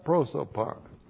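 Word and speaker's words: prosopon (πρόσωπον): P-R-O-S, long O, P-O-N. Kata prosopon.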